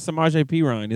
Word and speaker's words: Samaje Perine: That's how I felt about Samaje Perine.